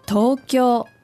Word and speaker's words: Tokyo: Tokyo.